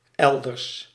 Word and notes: ailleurs: France; [a.jœʁ]

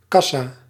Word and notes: kassa: /kɑ.sa/

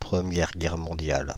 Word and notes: Première Guerre mondiale: pʁə.mjɛʁ ɡɛʁ mɔ̃.djal

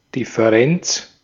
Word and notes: Differenz: /ˌdɪfəˈrɛnʦ/

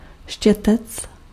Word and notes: brush: American English; /bɹʌʃ/

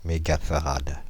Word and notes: mégafarad: [me.ɡa.fa.ʁad]; Paris

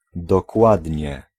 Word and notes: precis: /prɛˈsiːs/

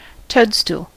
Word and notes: Schwamm: IPA: /ʃvam/; German

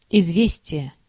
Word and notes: news: /n(j)uːz/; American English; [njuːz]

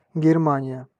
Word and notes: Saksa: /ˈsɑk.sɑ/

